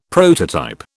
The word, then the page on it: prototype: British English